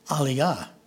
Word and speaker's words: aliyah: And I grew up, it was always the Aliyah, not realizing that it was a mispronunciation. And it's really Aliyah, meaning they came up to come up.